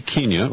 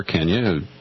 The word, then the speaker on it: Kenya: First up, I wanted to get your take on the recent conference in Nairobi, Kenya, or Kenya, depending on whether you've got a British influence on your pronunciation.